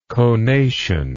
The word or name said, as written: conational